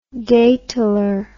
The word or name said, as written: daytaler